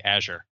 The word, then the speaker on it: Azure: You just answered my first question, which is, what's the proper way to say Azure? – I say Azure because I'm really into like mineral collecting, and my favorite mineral is Azurite.